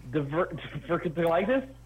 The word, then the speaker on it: diverticulitis: Shane has diverticulitis.